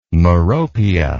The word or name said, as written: meropia